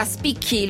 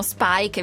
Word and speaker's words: Spike: Eccolo qua, Spicchi, lui stesso prende in giro un po' gli italiani per come pronunciano Spike. Evidentemente quelli che conosce lui dicono Spicchi.